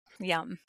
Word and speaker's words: Yam: So it's Y-A-M. It looks like yam, but you pronounce it yum, like yummy. And you say it like om.